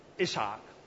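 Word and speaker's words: Yitzchak: Okay, and God gives the name of their child that is to be born, Ishak. You want to say it properly.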